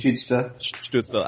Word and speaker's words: Stützle: And I think if they go with Stützle at Stützle, how do you say it correctly? – Stützle.